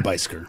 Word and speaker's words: Beiseker: B-E-I-S-E-K-E-R. – Bicycle. – Bicycle, Alberta. – Have you been to Bisker? – Yeah, I've been to Bisker. – Oh, you have? – Crossfield had a little beef with Bisker.